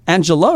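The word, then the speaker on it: Angel-A: And is it pronounced, is it Angela or Angela? – Well, I mean, her name was Angela. – Okay. – But Angel A is the title, because that's actually her position in life.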